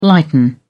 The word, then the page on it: lighten up: British English